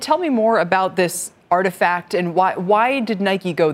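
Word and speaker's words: RTFKT: Nike has purchased RTSKT, pronounced Artifact Studios.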